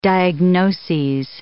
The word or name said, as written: diagnoses